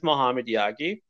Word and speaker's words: Mohammed Yaghi: My name is Mohammed Yaghi, I pronounce it like an American just because that's how people understand my name then.